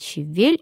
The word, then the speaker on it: щавель: Свекла по-прежнему свекла, и ударение падает на ее, а щавель есть щавель, и долго еще эта норма будет держаться в русском языке.